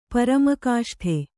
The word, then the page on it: ಪರಮಕಾಷ್ಠೆ: Kannada